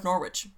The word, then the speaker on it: Norwich: And Norwich Castle, which is the burial place of executed Rush, and I am not confident on my pronunciation of Norwich. – How else would you say it? – Englishy?